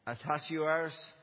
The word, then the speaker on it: Ahasuerus: the son of help me out, somebody pronounce it for me. Ahasuerus. Yeah.